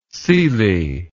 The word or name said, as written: syli